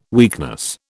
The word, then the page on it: weakness: British English